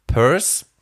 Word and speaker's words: Peirce: Einer ist der Charles Santiago Sandres Peirce. Ähm, ähm, Pierce quasi geschrieben, aber Peirce ausgesprochen.